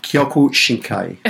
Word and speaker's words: Kyokushinkai: Kyokushinkai.